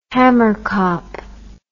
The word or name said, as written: hamerkop